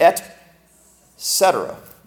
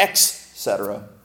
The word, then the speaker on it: et cetera: And the actual pronunciation is "et cetera," not "ex cetera."